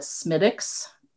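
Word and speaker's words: Smithwick's: And so it was a, well, they call it Smittix, but it's spelled Smithwix. – Yeah. – Smittix beer.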